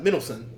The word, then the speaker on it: Mendelsohn: Mendelsohn.